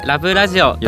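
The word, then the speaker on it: LABBラジオ: はいはいはい、 LABB ラジオと書いてラブラジオと、読みます。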